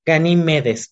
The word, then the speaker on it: Ganímedes: Pues curiosamente, aquí ponen a Ganímides. Ganímedes, perdón, lo pronuncié mal.